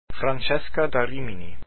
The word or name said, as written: Francesca